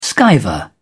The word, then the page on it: skiver: British English